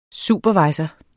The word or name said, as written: supervisor